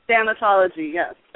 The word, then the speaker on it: thanatology: Did I pronounce that right, thanatology? – Thanatology, yes. – Thanatology, okay. I stuck an extra N in there.